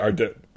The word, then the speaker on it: Ardennes: Yeah, Ardennes.